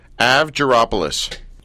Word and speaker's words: Avgaropoulos: First of all, how do you pronounce Marie's last name? – Avgaropoulos. – Avgaropoulos.